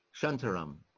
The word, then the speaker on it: Shantaram: Shantaram.